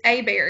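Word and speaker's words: Hébert: Well, I'm gonna correct you. It's A-Bear. It's a Cajun. – A-Bear. – But yeah, it's spelled Herbert, but yeah, it's A-Bear. I've got family down in South Louisiana who would shoot me if I didn't correct that.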